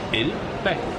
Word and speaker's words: Elba: Paden, der udkom her i sommer, den hedder Elba. Er det så udtalt rigtigt? – Ja, det er helt korrekt.